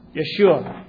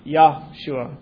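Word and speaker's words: Yeshua (ישוע): It is pronounced Yeshua, not Yahshua.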